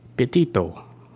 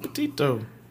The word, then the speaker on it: Petito: Today's main topic, we're going to be talking about Gabby, Gabrielle, Petito. – There you go. – Said it right. – Petito.